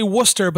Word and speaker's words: Worcester: We're the same four assholes who say Worcester, but there's a fucking C in there that nobody knows how it got there.